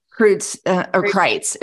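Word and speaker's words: Kreuz: you're probably looking at Black's and what's the Kreutz's? Am I saying that right? – Kreutz. It's Kreutz.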